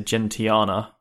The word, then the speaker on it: Gentiana: Uh, there's lots of different pronunciations running around for this, but I'm going to say Gentiana.